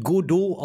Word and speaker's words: Godot: Es wird Godot ausgesprochen und es ist in C++ geschrieben.